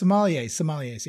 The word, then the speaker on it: sommeliers: No, these were like the top sommeliers, or how do you ever pronounce it? – Oh, yes. Sommeliers.